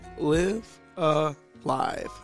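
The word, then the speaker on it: Live A Live: First of all, Live Alive, that is how you pronounce it, right? – Live Alive, yes. – Okay. – And it's spelled L-I-V-E space A space L-I-V-E.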